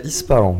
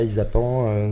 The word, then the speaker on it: Ispahan: Non non non, c'est Ispahan. Voilà, pas Isapan ni Isafan, c'est Ispahan.